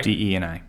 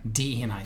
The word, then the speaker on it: DE&I: And you make a connection here, ID&S, right? That's what we had to, is that, did I say that right? – DE&I. – DE&I.